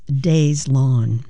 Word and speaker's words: Deslondes: It's spelled Deslondes, but it's pronounced Deslondes.